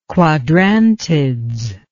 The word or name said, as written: Quadrantids